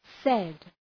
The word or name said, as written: said